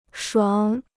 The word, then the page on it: 爽: shuǎng